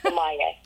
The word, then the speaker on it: sommelier: And one of those things that she has done was become, and I'm going to say this correctly this time, sommelier. Did I say that right? – Yeah, sommelier.